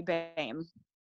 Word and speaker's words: Bame: It's BAME. – BAME. Oh my God. I'm still not nailing it. – Well, it's okay. It's German. So it's like spelled weird.